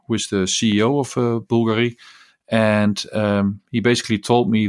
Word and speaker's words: Bulgari: It was Hublot, Zenith, and Bulgari, or Bulgari, as they pronounce it themselves.